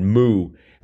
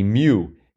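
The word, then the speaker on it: mu: And one of the clues this morning had moo, and that was supposed to be mew. Everyone knows that that moo is not how you pronounce the mew of the Greek alphabet.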